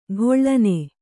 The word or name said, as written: ಕಳನಿ